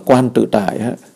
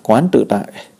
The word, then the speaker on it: Quán Tự Tại: Mình cũng có thể đọc là Quan Tự Tại, là Quán Tự Tại.